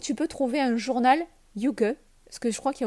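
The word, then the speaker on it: hygge: Euh, ensuite, je peux te proposer aussi, je ne sais pas si tu connais le Yuge, ça s'écrit H-Y-G-G-E. C'est un terme danois et norvégien.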